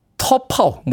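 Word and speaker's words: T'Pau: 정확한 발음으로는 터파우, 뭐 이렇게 발음을 해야 된다고 하는데, 우리나라에서는 티파우라고 발음합니다.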